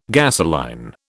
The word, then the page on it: gasoline: British English